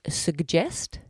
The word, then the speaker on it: suggest: They pronounce both the G's and both differently. So it's suggest. So the first G is a G and the second G is a J.